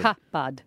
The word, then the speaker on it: cupboard: And then read out how they say to say cupboard. – Cupbud. – Yeah, cupbud.